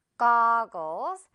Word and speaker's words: goggles: 고글스 아니고요. 우리 이제 어 발음 또는 아 발음 길게 갈 거예요. goggles.